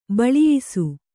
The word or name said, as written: ಬೋಳಯಿಸು